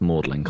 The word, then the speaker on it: Magdalen: Although I will point out, it's Magdalen. It's spelt Magdalen, but it's pronounced Magdalen College.